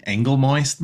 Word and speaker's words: Angolmois: Princess Moon is like, no, it's pronounced Angle moist. Uh and I'm like, yes, that is how it's pronounced.